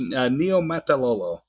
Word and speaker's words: Niumatalolo: Is that how you pronounce his name? – Yeah, pretty close. Niumatalolo.